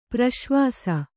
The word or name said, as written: ಪ್ರಸವಿಸು